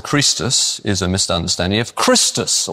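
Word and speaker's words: Chrestus: Well, Suetonius tells us because they were squabbling over one called Christus, spelt with an E in Suetonius's work, and it's pronounced with a long E, so very like how it would have been pronounced if it was an I, Christus as well.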